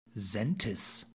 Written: saentis

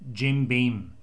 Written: Jim Beam

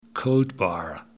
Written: Gold Bar

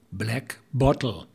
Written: Black Bottle

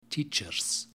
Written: teachers

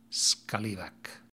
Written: scallywag

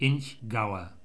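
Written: Inchgower

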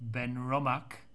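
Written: Benromach

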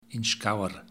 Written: Inchgower

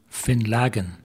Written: Finlaggan

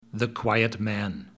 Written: the quiet man

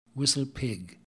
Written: whistle pig

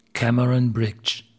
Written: Cameron Brig

